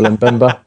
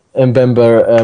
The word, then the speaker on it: Mbemba: I know there's a bit of talk today on Sky Sports about, I'll pronounce it for you because I know you struggled earlier, Chancel Mbemba. – Mbemba at Newcastle.